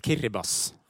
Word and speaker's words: Kiribati: Jeg hadde sagt Kiribati om jeg fikk valget, men det ikke sånn det skal sies. – Nei, det riktig. Og det uttales rett og slett Kiribas. Og dette jo helt umulig å få inn i hauet hvis du vestlig, holdt det på seg, og lese vårt alfabet.